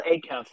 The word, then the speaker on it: Acuff: You did, Will Acuff.